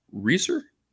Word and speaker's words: Resar: Is it James Reeser? – Yep. – Glad I got that pronunciation right.